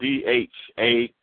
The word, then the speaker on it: Dharma: Dharma is actually, like, if you go to Google and Google Dharma, it's spelled DHARMA. It's spelled like karma, but it's Dharma, DHARMA. It rhymes with karma.